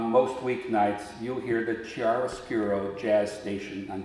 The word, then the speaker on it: chiaroscuro: It's pronounced chiaroscuro.